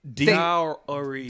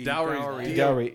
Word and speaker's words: dowry: How are you saying it? – Dowry. – Dowry. – D-O-W. – Say it again? – D-O-W-R-Y. – Okay. – It's like rule.